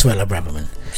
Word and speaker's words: Suella Braverman: Have I said it right? – I have no idea. – It is Suella Braverman.